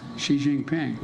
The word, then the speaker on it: Xi Jinping: I was in the foothills of the Himalayas with Xi Jinping.